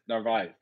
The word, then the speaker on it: Narvaez: Narvaez.